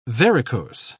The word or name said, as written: varicose